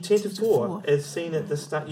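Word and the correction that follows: Tantive: Well, that's pronounced Tantivy.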